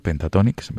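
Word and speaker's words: Pentatonix: No sé si está bien pronunciado, Pentatonics, ¿verdad? – Sí, sí.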